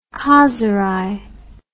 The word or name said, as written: khazeray